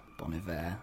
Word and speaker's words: Bon Iver: It also features Bon Iver, Bon Iver, as you would like to pronounce it. As, as it's meant to be pronounced.